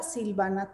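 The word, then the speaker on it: Sylvanna: Yo solo utilizo Instagram, no utilizo ninguna otra red social y, y es arroba Silvana Toledo. Silvana se escribe con Y y con doble N,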